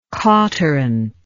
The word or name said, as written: cauterant